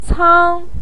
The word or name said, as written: cang1